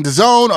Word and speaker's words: DAZN: Are you saying DAZN? Are you saying DAZN? – Like, no, you said it right the first time. Everyone says DAZN.